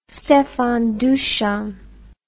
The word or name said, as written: Stefan Dusan